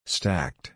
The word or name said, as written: stacked